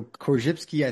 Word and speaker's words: Korzybski: You've mentioned, I never know if I'm pronouncing this right, the Orzabowski, the general semantics. And I know that James Joyce was a huge influence on Bob. Who were some of his other major influences? – Yeah, so Korzybski, I think,